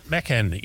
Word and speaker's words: Macan: Macan, yes. Now, by the way, the Taycan has Turkish language roots and roughly means soul of a spirited young horse. – No. – Now, you said Macan, and of course it's not Macan.